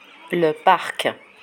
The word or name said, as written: le parc